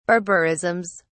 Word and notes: barbarisms: British English

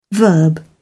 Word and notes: verb: British English

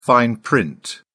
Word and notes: fine print: British English

